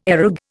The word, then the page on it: areg: Indian